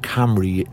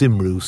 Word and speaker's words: Cymru: Yesterday we gave a talk at the National Museum in Cardiff to RNIB Cymru, is how it's pronounced, Cymru, C-Y-M-R-U, if you, I suppose, pronounce Welsh like I do.